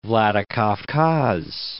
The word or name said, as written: Vladikavkaz